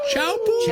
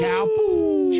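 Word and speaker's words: Teahupo'o: Believe it's pronounced Chaupu. – Chaupu.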